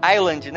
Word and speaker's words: Island: Como é que eu pronuncio direito Oak Island? Island, né? Não tem... Por que que o americano faz essas porra? Por que que... Então, não tem um S ali?